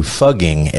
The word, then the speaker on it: Fugging: They're tired of English-speaking tourists flocking to pose, sometimes grotesquely, in front of the town sign, so they have now officially changed their name to Fugging, F-U-G-G-I-N-G, which rhymes with mugging. So it's Fugging Austria, not fucking Austria.